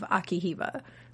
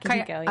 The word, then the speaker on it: Akihiko: And I thought it manifested into, like, this character that was, like, maybe controlling it on behalf of Akihiba. Or did I say his name? – Kayaba right? Akihiko.